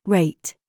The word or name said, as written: TIGHT